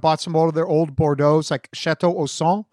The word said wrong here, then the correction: Ausone: I probably mispronounced it, Ausone.